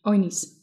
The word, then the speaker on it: Oignies: Oinis? Maybe, because I don't think you pronounce the G. Oinis.